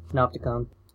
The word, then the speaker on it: Panopticon: Panopticon.